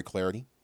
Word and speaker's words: Clardy: Also, give me the follow at Troy Clarity, last name is spelled C-L-A-R-D-Y.